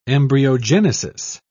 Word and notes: embryogenesis: British English

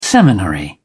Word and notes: seminary: British English

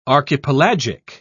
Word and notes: archipelagic: British English

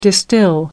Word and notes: distill: British English

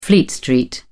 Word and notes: fleet street: British English

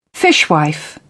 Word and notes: fishwife: British English

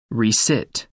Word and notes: resit: American English

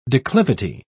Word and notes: declivity: British English

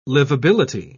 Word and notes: livability: British English

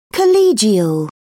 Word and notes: collegial: British English

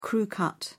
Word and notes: crew cut: British English

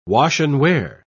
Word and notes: wash-and-wear: British English

